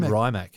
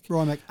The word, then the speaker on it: Rimac: Oh, you said Rimac? I said Rimac. – Rimac, R-I-M-A-C.